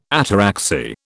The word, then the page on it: ataraxy: British English